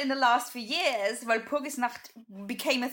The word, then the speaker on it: Walpurgisnacht: She ended up at Walpurgisnacht.